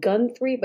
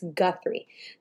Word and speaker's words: Guthrie: I keep wanting to put an N in there, like Gunthrie, but it's Guthrie. So the interview was pretty intense. I really liked that Ms. Guthrie was very, cut point.